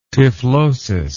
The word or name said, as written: typhlosis